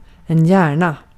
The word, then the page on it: hjärna: [ˈjæːɳˌa]; /ˈjɛːrˌna/